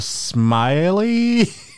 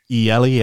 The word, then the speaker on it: Mieli: But I'm going to hazard Mara Smiley. – Mieli, I think probably. – Yeah. – Mieli, yeah.